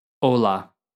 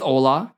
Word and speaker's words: hola: Hola. With hola, keep in mind that it's spelled H-O-L-A, but in Spanish, the H is silent at the beginning of the word.